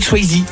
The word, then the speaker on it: Swayze: Toi tu dis Patrick Swayze. – Ah, je dis Patrick Swayze. – Voilà, parce que le E c'est I en anglais.